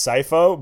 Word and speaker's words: Sipho: How do you say that name? – I believe it's Sipho, but Tyler.